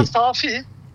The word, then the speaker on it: Safi: Ya soyadının okunuşu. Hakan Safi normalde. Tamam. Ama şimdi Safi dememizin sebebi o Safi Port'u bu şekilde söylüyoruz Safi Port diye.